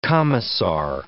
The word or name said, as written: commissar